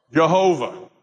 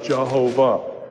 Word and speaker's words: Jehovah: It's this, I'm going to pronounce the Hebrew letters in English with the vowel pointing of Adonai, and the way it comes out is Jehovah.